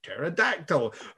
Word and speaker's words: pterodactyl: And they're like, this is a triceratops, and this is a diplodocus, and this is a pterodactyl. It's spelt with a silent P, though.